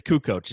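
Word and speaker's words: Kukoč: It's Kukoc.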